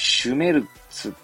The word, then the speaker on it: Schmerz: これシュメルツって読んだと思いますが、合ってると思います。ちなみにスペルは、 S, C, H, M, E, R, Z ですね。多分シュメルツでいいと思いますね。